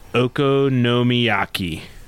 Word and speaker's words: okonomiyaki: So, smoked goose leg ham okonomiyaki.